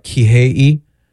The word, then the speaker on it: Kihei: All right, Kihei. – Perfection. – Am I butchering it? – You're Kihei.